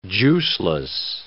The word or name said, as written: juiceless